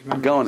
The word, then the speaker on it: Gaon: Gaon is gaon. everything. – How do you pronounce it? Gaon.